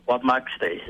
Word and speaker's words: Wapmaxte: It's actually Wapmaxte. It means the river of the whales in Cree.